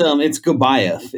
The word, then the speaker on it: Gabaeff: No, it's Gabaeff is the last name.